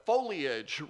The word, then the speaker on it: foliage: And one time we were driving down US 50, and I just remarked to Bethany, I just love the foliage here in Maryland. And she says to me, you mean the foliage, right? Yeah, the foliage, Bethany, the foliage. No, it's three syllables, foliage.